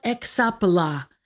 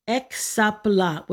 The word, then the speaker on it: hexapla: It's called exapla, H-E-X. The H part is a breath mark. It's not pronounced in Greek. H-E-X-A-P-L-A, exapla with an H, hexapla. It would look like hexapla.